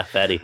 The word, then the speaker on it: Fady: Yeah, Fady.